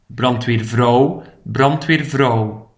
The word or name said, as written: de brandweervrouw